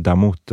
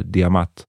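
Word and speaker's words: D'mt: Långt innan Axum härskade ett annat kungadöme i den här regionen Namnet skrivs ut som D följt av en apostrof och MT och det uttalas ungefär som antingen Damut eller Diamat.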